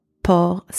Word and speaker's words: porc: In the second word, port, spelled P-O-R-C, means pig or pork. Same pronunciation as the one before, except this time it is the final C that is silent. Final pair of words, we have dans and dans. First word, dans, spelled D-A-N-S, means in.